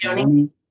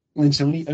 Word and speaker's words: Lencioni: I think Patrick uh, Lynch- Lencioni. – Lencioni. Okay, I always mispronounce his last name.